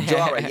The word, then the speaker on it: Jorah: It was, you know, when Mr. Mormont, I want to say, Joa of Mormont. Okay, let me not get these pronunciations. – Jorah. – Right. Jorah, yes, good old Jorah.